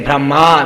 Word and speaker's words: Brahma: So when we say Brahma with a long A, it refers to Lord Brahma we're talking about. And if you use the short A, it's actually Brahman.